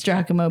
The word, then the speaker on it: Giacomo: I know it's Giacomo Puccini.